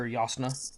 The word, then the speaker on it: Jasnah: And I hope that they talked with Brandon Sanderson and confirmed that is Yasna. because I would have read it as Jasnah if I wasn't expecting it to have like a Germanic pronunciation. But I assume Jasnah is the correct pronunciation.